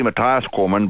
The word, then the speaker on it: Matthias Cormann: Matthias Cormann. – Hello. Good morning. It's actually Matthias Cormann, but you can pronounce it any way you choose to.